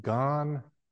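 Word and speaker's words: gan (願): And I'll just say try to say this really quickly, but gan is the Japanese pronunciation of the Chinese character for vow.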